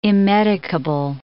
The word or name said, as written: immedicable